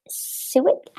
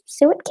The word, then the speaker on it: suet: They feed it with suet. I don't know how to pronounce this. How do you pronounce this? Suet cakes? – Yep, that's right.